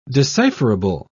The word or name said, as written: decipherable